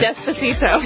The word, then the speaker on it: Despacito: Called Despacio. Is that how you pronounce it? – Despacito. Not Gazpacho.